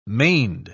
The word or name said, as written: maned